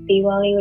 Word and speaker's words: Diwali: But there's like two different pronunciations of it. There's Diwali, which is the North Indian pronunciation, and then there's Deepavali, which is the South Indian pronunciation.